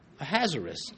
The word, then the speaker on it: Ahasuerus: You want to remember how to pronounce his name, just remember he has a wrist, Ahasuerus.